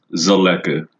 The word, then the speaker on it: Zeleke: Um, how do you pronounce your last name, real quick? – It's pronounced Zeleke.